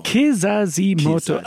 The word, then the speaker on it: Kizazi Moto: Kizazi Moto.